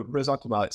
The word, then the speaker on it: ressentiment: pardon my semi-French pronunciation, but ressentiment. – Yeah. – Please pronounce that correctly and tell me what you mean by it. – Oh, I'm not a perfect French speaker either, but no, ressentiment.